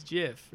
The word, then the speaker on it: GIF: It's pronounced with a J. It's GIF, right?